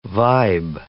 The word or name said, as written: vibe